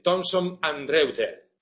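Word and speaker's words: Thomson Reuters: La última edición de la editorial Aranzadi, tradicional, ahora ya ha sido absorbida, comprada por la editorial estadounidense, no sé si lo voy a pronunciar bien, Thomson & Reuters. Era. Bien. Sí, por lo menos de lejos.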